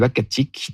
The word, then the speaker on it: Uhackatic: Et donc, on s'est mis d'accord sur Wakatik, qui désigne un endroit propice à la chasse du caribou.